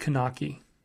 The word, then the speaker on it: Kunaki: Now, with a service like Kunaki, and if you were like, what word is that?